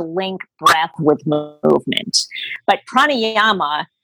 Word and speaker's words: pranayama: Am I saying that right? – Yes, it's pranayama. So if you've ever been,